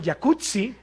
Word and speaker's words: Jacuzzi: Oye, así que el jacuzzi. – Jacuzzi. – Entonces deberíamos pronunciar el jacuzzi. – Exacto. – Como pizza. Como Rampazzo, como Claudia Rampazzo.